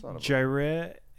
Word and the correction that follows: Jireh: Edwards, or how do you pronounce that? – Edwards. Jariah. – Jariah. – Jariah Edwards. J-I-R-E-H. Jariah Edwards.